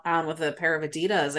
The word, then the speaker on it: Adidas: It's Adidas, by the way. – Yeah. – It's Adidas. – Yeah. – You and your Scottish pronunciations. Adidas.